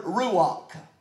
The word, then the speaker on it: ruach: That word Spirit in the Hebrew, to be able to pronounce Hebrew the way it was intended, I've often heard that you have to talk like you have a hairball stuck in the back of your throat to really get close to the Hebrew pronunciation. Well, I don't have that. But the Hebrew or the redneck version of this word is the word ruach.